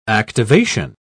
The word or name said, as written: activation